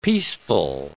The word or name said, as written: peaceful